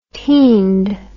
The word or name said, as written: teind